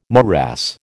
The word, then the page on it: morass: American English